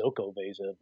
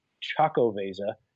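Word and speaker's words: Xocoveza: I said it wrong every time. I used to call it the Zoco Vesa, but it's a silent X. Choco Vesa,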